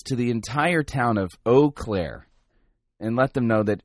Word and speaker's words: Eau Claire: Yeah, I remember the other day, Kyle wrote me and I couldn't pronounce the name of his town. I was Eclair. I thought it was Eclair. Now, apparently I was severely mispronouncing this. It isn't Eclair.